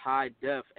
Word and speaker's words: Hi-Def: But you can search on every platform, Legend by High Def, HI-DEF, just like how my name is spelled on every other platform.